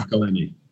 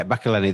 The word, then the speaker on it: McElhenney: McElhenney. – Yeah, McElhenney, that's the one.